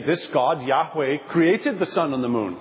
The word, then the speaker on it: Yahweh: and now scholars believe that it ought to be pronounced Yahweh, which sounds in Hebrew like the verb to be, I am.